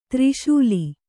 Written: ತೆರೆಸೇಲೆ

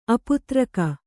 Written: ಅಪುತ್ರಿಕ

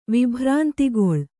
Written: ವಿಭ್ರಾಂತಿಗೊಳ್

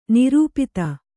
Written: ನಿರುಪಾಧಿ

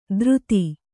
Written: ದ್ರುತಿ

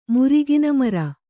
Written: ಮುರಿಗಿನಮರ

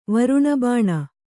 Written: ವರುಣಬಾಣ

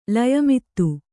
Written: ಲಯಮಿತ್ತು

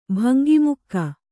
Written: ಬಂಗಿಮುಕ್ಕ

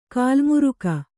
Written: ಕಾಲಮುರಿಗೆ

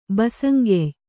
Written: ಬಸಂಗೆಯ್

